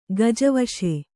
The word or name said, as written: ಗಜವಶೆ